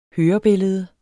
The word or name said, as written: hørebillede